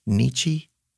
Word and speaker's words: Nietzsche: Somebody said, I think it was Nietzsche. Is that how you say it? Frederick Nietzsche? – Yeah. – How would you say it? – Yeah, just like that.